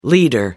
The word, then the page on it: leader: American English